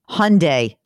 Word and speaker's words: Hyundai: Hyundai rhymes with Sunday.